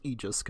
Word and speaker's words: Aegis: 13 Sentinels Aegis Rim, which turns out I was right, it's pronounced Aegis, because they say it in the game.